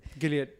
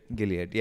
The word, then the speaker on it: Gilead: Gilead. – Gilead, yeah.